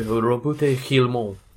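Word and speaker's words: Roboute Guilliman: I always think he should be pronounced with um, a, a really French accent. It should be it Yeah, it should be Robute Guillemot or something like that.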